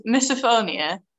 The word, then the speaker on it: misophonia: I've only ever seen it written down, um, so, but I think uh, I think I've got the pronunciation. It's so it's misophonia,